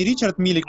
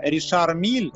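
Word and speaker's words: Richard Mille: Не Ричард Миллер, а на самом деле это Ришар Миль.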